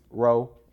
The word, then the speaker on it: Ro: and you know so i'm embracing my actual name you know my last name is you know confusing to people how do you say it so i just row you mm-hmm.